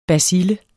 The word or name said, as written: bacille